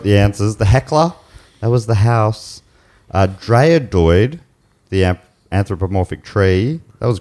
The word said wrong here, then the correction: Dryadoid: Am I saying that anywhere near right? – Dryadoid.